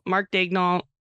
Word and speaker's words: Mark Daigneault: We both have Mark Daigneault.